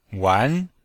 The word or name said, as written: wan2